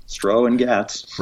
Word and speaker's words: Strogatz: It causes some problems from time to time because it looks intimidating with the strange consonants in it, but just two syllables, Stro and Gats.